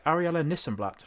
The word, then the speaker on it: Arielle Nissenblatt: Today I'm joined by Arielle Nissenblatt from Squadcast.